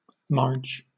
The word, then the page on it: marge: British English